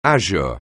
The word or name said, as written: azure